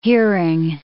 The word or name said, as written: hearing